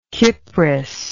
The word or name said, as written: Kypris